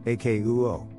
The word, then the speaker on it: akouo: Hear comes from the Greek word apostrophe kappa omicron omega, which is transliterated as akouo, and is therefore pronounced as akouo.